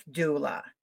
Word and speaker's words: doula: Now, you are a grief doula. Am I saying that correctly? – Doula. – A grief doula. D-O-U-L-A.